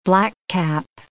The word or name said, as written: blackcap